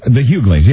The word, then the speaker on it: The Hughleys: Hughley's. Is that how you pronounce it? – The Hughleys, yes.